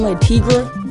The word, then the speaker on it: La Tigra: I'm rocking the green La Tigra with. – Oh, hold on, it's not La. La Tigra. Like, say it like it's one word.